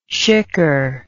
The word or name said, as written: shicker